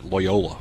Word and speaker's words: Loyola: That is how you pronounce that, Loyola. – Not Loyola. – I struggle with that for years.